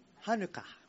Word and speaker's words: ハヌカ: I don't know how you say it. ハヌカ。 – ハヌカ。 – Okay, probably in Jewish, ハヌカ。 – ハヌカって言う。 – Okay. – ユダヤ語では。That's how Jewish people speak.